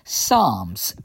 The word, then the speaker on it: Psalms: And one of the prayers we learn is one of the Psalms, P-S-A-L-M. It's a silent P. I never understood that silent P, but it is there.